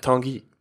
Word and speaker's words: Tanguy: Men man har sålt Tongai Ndombele till Tottenham. – Tanguy. – Tanguy, ja, du kan få ta det uttalet här.